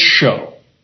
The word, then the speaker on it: shew: It's Old King James translation, or Old King James spelling, S-H-E-W, but it's "show."